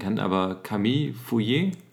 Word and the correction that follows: Fournier: Fournier, oh, ja. – Fournier. Ich glaube, da ist ein N drin.